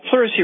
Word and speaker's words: pleurisy: Hey, Dr. Wallach, here's an email from Scott who says his wife has pleurisy. Let's make sure this is the correct pronunciation and spelling, P-L-E-U-R-I-S-Y. Does that sound about correct? – Yes, sir.